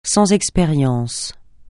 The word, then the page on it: sans expérience: francais